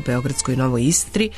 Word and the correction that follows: Iskri: Iskri, hoću li više izgovoriti Novoj Iskri.